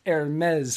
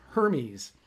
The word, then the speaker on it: Hermes: That is pronounced Hermes for anybody who doesn't know that. I had to work on that all day today because it looks like it's pr- pronounced Hermes co-sign with them or cons,